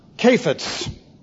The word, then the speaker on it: kephetz: And it's pronounced kephetz. That would be C-H-E-P-H-E-T-Z.